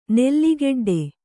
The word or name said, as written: ನೆಲ್ಲಿಗೆಡ್ಡೆ